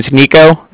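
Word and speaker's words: Niko: My name's Nico. That's N-I-K-O. – Okay. – Calling from Cardiff. That's spelled C-A-R-D-I-F-F, as in Frank Frank.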